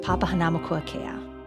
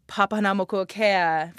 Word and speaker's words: Papahanaumokuakea: with them in this offshore postcard from Papahanaumokuakea.